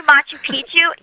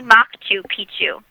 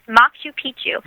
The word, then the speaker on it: Machu Picchu: It's actually Machu Picchu. And so to me, now that I know, like, I can kind of chuckle, because See, like, it always ends up somehow going back to this idea of inappropriate material. – So the correct way to say it is Machu Picchu? – Yes, Machu Picchu. – With a and hard C in there.